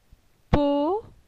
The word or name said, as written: Po